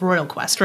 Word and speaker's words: Royal Quest: at Royal Quest, right? I'm saying that right? – Yes.